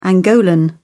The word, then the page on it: angolan: British English